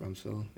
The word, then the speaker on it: Rumsfeld: Rumsfeld? – Rumsfeld, yeah.